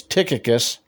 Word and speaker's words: Tychicus: He says, Tychicus, yes, that's how you say his name,